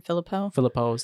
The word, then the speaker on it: Philippou: And director Danny Philip, how do you say his name? Filippo, something like that, yeah.